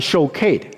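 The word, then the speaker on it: shokid (שֹׁקֵד): 杏树在希伯来文的读音是 shakid，留意保守在希伯来文的读音呢是 shokid，差不多的 shokid shokid。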